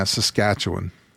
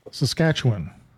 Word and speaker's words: Saskatchewan: Did I pronounce that correctly, I hope? – Yeah, Saskatchewan. – Saskatchewan. Yeah. Okay. – There you go. Perfect.